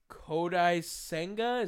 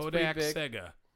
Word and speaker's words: Kodai Senga: And then Kodai Senga, is that how you say his name? – Yes. – Yeah, he's going to the Mets. That's Kodak Senga. – Is that how it is? – No.